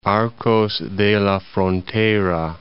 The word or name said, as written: Arcos de la Frontera